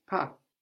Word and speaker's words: pas: But then we also have this pas on the end, which is spelled P-A-S. Pas, P-A-S, is related to pass, passage, passage, meaning something like step, But it has just one S, and that S is silent, as we hear now. Pas. Je ne veux pas.